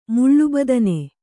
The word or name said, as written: ಮುಳ್ಳುಬದನೆ